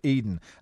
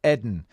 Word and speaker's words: Eden: Eden Rees says, can you please tell the rest of the footballing world to pronounce Eden Hazard like the Garden of Eden and not Eden?